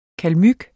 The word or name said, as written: kalmyk